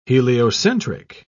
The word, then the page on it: heliocentric: British English